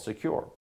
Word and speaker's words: Sekur: That's why you need a new privacy and cybersecurity application tool called Secure. It's spelled S-E-K-U-R.